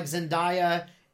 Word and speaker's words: Zendaya: and I'm wondering if I'm gonna like Zendaya. And I know I would never say her name right, do I? Is it Zendaya? – Yeah.